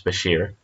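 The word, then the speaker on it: bichir: The term Bashir, pronounced Bashir, is derived from a name locals gave to the fish, thought to relate to a description of a whip.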